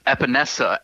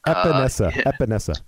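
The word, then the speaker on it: Epenesa: AJ Espensa. Correct me if I pronounce that wrong, because phonetics is my Achilles heel. But defensive end, second round, edge rusher, hit or miss for you. – It is Epinesa, actually.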